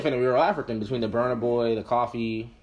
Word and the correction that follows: Kofi: or Kofi. Sorry, I keep saying that wrong. Kofi, yes.